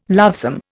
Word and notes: lovesome: Indian

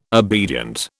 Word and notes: obedient: British English

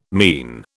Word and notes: mien: British English

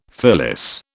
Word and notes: fillis: British English

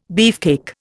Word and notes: beefcake: Indian

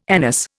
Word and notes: anise: Indian

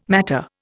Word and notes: matter: Indian

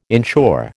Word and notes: insure: American English

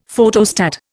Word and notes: Photostat: Indian

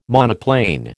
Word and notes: monoplane: American English

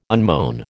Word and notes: unmown: American English

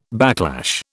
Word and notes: backlash: British English

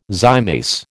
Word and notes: zymase: American English

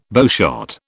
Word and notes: bowshot: British English

